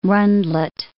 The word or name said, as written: runlet